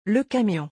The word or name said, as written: le camion